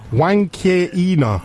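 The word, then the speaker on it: Huancaína: Pronounce it Huanqueina. – Yeah. – Juan as in Juan Bisaca.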